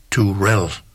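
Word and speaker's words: Terrell: Tammy var korrekt nok, men efternavnene, det udtales faktisk Turell. Altså Tammy Turell hedder hun, selvom det staves T-R-R-I-L-L.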